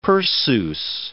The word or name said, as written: Perseus